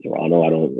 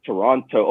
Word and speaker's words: Toronto: And you know, in the US, they pronounce, because in Canada, we don't pronounce like the second T's in most of our statements. So they're like, Toronto.